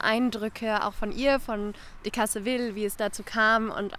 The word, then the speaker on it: Decazeville: Decazeville.